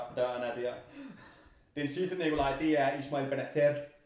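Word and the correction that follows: Bennacer: Prøv lige at sige det igen. Ismail Benazer. – Øh, Fuck, hvor jeg håber, det ikke udtales sådan der. Ham ved jeg, Benasser. Benasser. Ismail Benasser. Nå, ham ved jeg, du har en holdning til.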